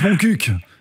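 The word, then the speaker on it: Montcuq: Ça se prononce Moncuq, en fait.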